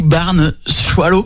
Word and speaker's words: barn swallow: Et dans son anglais, alors, t'es peut-être meilleure que moi en anglais, ça se dit barn swallow. – On prononce pas le R. Barn swallow.